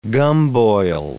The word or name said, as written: gumboil